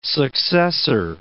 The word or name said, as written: successor